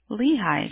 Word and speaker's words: Lehi: L-E-H-I. – Oh, Lehi, just like that. – Yeah, it's Lehi.